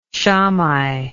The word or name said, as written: shammai